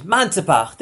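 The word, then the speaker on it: Manzapach (מנצפ״ך): Manzapach, different ways to pronounce it, but it's really Manzapach.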